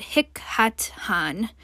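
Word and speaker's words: Thich Nhat Hanh: This comes from the book called Anger by the Buddhist monk, I believe you pronounce it Hik Hat Han, but I will put this in the show notes.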